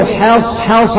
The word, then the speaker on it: حصحص: وكذلك أيضا الآن حصحص الحق، البعض يقرأ حصحص يبقى فخم الحاء، ولكن الحاء تكون مرققة حتى تتميز من الصاد المفخمة، فننطق حصحص الحق، ما تقولش حصحص وتفخم الحاء، لا حصحص. ولا تلبس الحق، ما تقولش الحق بتفخيم الحاء، ولا تلبس الحق بالباطل.